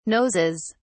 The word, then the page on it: noses: British English